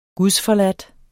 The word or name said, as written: gudsforladt